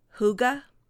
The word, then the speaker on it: hygge: Because it's spelled H-Y-G-G-E. I mean, how do you get Hygge from that?